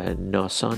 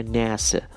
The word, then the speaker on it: NASA: بگذاریم که در فارسی این رو کاملا غلط ما تلفظ میکنیم، اصلا چیزی به نام ناسا وجود نداره، هیچ انگلیسی زبانی ناسا نمیگه، این در واقع ناسه هستش، ناسه.